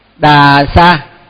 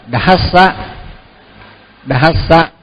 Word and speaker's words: Sambuddhassa: Thì mình đọc á là thường á là vì mình đọc quen á, rồi mình cứ đọc là Đà Sa, nhưng mà Đà Sa là không đúng, thì mình phải đọc là Dhassa Dhassa mới đúng.